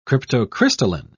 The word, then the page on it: cryptocrystalline: British English